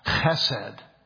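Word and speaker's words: chesed (חסד): A very special Hebrew word called chesed. It's pronounced like a ch, a hard ch, E-S-E-D.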